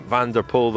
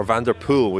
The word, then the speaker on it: Van der Poel: I was fascinated by that line from Van der Poel, though, because or Van der Poel, we've been told off for pronunciation,